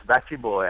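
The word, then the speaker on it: Batty Bwoy: The B-W-O-Y put me off for a second. But is it Batty Boy? – Yes, it's Batty Boy, and it's Max Arthur Mantle.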